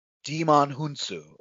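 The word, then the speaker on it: Djimon Hounsou: Is it Jimon? – Demon Hunsu, I believe, is the pronunciation.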